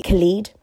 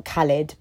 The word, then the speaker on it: Khalid: So my tune of the day today is quite a newish song, and it's by Khalid. I'm sure that's how you say it. I'm sure it's not Khalid, but it's Khalid. I'm more than sure.